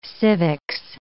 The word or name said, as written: civics